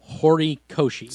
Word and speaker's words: Horikoshi: Hirokoshi or Horo. Horikoshi.